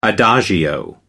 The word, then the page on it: adagio: American English